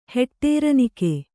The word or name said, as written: ಹೆಟ್ಟೇರನಿಕೆ